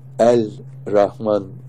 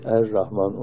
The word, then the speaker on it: Er-Rahman: Birisiniz tamlama olduğu zaman lamlar ra olur. El-Rahman kelimesi Er-Rahman olarak okunur.